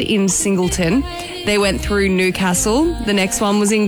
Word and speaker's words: Newcastle: Bit of a shame on the pronunciations there. It could have been better. Newcastle, it's pronounced.